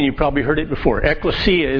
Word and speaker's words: ekklesia: The Greek word we pronounce, and you've all heard it, ekklesia, right?